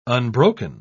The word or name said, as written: unbroken